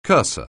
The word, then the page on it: cursor: anglais